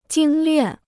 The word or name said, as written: 精练